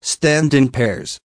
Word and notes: stand in pairs: American English

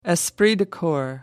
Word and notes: esprit de corps: American English

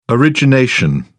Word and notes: origination: British English

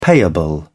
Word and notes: payable: British English

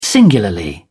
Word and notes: singularly: British English

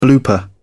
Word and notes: blooper: British English

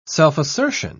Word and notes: self-assertion: British English

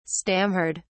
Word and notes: stammered: British English

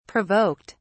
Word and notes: provoked: British English

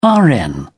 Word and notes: rn: British English

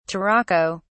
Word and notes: turaco: British English

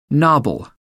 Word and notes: nobble: American English

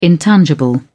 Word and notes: intangible: British English

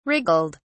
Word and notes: wriggled: British English

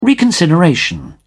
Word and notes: reconsideration: British English

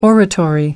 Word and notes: oratory: American English

